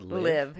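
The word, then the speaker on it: Liev: Oh, Liv Schreiber. Oh, fuck me. I'm so dumb. – That's how you pronounce that? – Liv. Yeah, it's Liv Schreiber.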